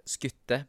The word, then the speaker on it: Skutte: Skutte, skutte, beklager. Jeg tror det er Skutte det uttales, er det ikke det, Alex? Jo, det er det.